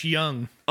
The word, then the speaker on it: Jung: The only person that he is behind is Josh Jung. – Young. – Oh, is that how it's pronounced? – You should know that.